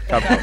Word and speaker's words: Cap Zero: En verano y tenemos a cracks como Cap Zero. – Capo, por favor. – Capo. – Sé que se escribe Cap Zero, pero es Capo.